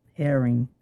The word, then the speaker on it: Herring: J-A-N-E-T. – And that's Janet, not Jeanette. – Janet. – And not Jackson. – Herring. – Spell that for me. – H-E-R-R-I-N-G. Like a fish.